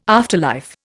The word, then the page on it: afterlife: Indian